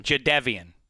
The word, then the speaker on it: Jadeveon: Watt because I think people are underestimating how much the loss of Jadeveon Clowney – did you see that? I pronounced his name right. Greg taught me how to say his name. – How do you say it? – Jadeveon.